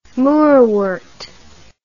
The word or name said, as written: moorwort